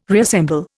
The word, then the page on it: reassemble: Indian